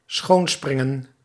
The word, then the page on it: schoonspringen: schoon·sprin·gen